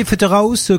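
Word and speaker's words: Pfetterhouse: Vous savez comment ça s'écrit? Non mais écoutez bien. Pfe2terhouse. Et vous prononcez ça comment? – Pfetterouze. – C'est très joli ceci étant dit, Pfetterouze.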